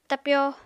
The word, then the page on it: tapyo: Apatani